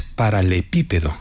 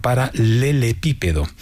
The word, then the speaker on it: paralelepípedo: No es paralelepípedo, como se oye con frecuencia, sino paralelepípedo.